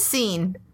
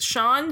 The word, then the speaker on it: Sean: And I'm not even going to pretend to you guys that the only reason I learned Sean is pronounced Sean, because Sean I grew up with had a W, uh-huh.